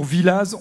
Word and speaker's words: Villaz: Ah, c'est toujours le débat en Haute-Savoie, hein, si on prononce les X, les Y à la fin ou les Z, et ici on le prononce. – Pour Villaz, on le dit.